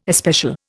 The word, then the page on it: especial: Indian